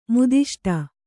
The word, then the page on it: ಮುದಿಷ್ಟ: Kannada